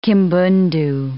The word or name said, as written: Kimbundu